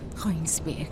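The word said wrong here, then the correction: Kreuzberg: اینطوری تلفظ میشه. – نه گروتزبرگ.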